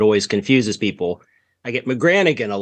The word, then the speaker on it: McGranaghan: McGranaghan. – McGranaghan. Okay. – It's confusing because there's a silent G in there that some of the members of the family who came to America dropped that and some did not.